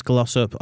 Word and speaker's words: Glossop: I am Travis Glossop on all socials.